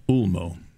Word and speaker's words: Ulmo: How do you say it? – Ulmo. U-L-M-O.